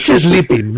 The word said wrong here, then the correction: spleen: ¿no? – No, es spleen. Es spleen. S-P-L-E-E.